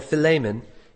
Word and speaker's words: Philemon: One that we have an entire book of the Bible written about is Philemon, Philemon, if you pronounce it right, who was a slave owner who had a slave named Onesimus.